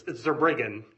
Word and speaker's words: Zerbriggen: How do you pronounce your last name? – It's Zerbriggen, Judge Davis. – Second syllable. Okay. Great. Go ahead. – May it please the court. My name is Adam Zerbriggen from the City of Philadelphia Law Department.